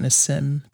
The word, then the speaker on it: Nassim: Nassim. – How do we pronounce that? I'll probably call it Nassim Taleb. – Nassim Taleb. – N-A-S-I-M-E.